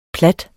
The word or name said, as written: plat